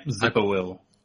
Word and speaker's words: Zipporwhill: With another satisfied client, they proceed on to the next one, which would be Zipperwill? Wow, is that how you pronounce her name? – Yep, Zipperwill.